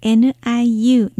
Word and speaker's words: 牛: 牛の発音は niu、牛にせ。